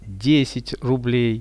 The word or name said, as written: 10 rublei